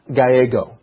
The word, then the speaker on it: Gallego: Spanish and Italian and other Latin languages, when you see two L's next to each other, often it's pronounced with a Y sound, and his name is George Gallego.